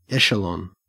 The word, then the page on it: echelon: Australian English